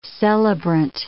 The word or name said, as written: celebrant